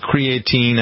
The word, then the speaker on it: creatine: And other things that are around that are commonly used, I'm trying to think, what is it, creatine? I never pronounced that right, creatinine, whatever it is.